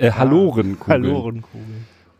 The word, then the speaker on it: Hallorenkugeln: Hallorenkugeln? Halorenkugeln. Das ist sogar richtig. Ich hatte gedacht, die spricht man Halorenkugeln aus. Ja, das klingt schon eher nach Halle an der Saale. Ja, aber ist wohl die korrekte.